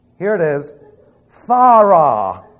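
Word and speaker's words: phthora (φθορά): P-H-T-H-O-R-A. It's like P-H-T-H. That's... No, it really isn't. Listen to it. It's phthora. Okay? I know you're going to have difficulty with it, but you're not Greek. Phthora. You're going to go phthora. And then you have to roll the R.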